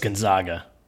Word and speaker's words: Gonzaga: Matt says it's pronounced Gonzaga. He just spelled it. – Got it. Yeah. Perfect. So it's Gonzaga. Final answer there.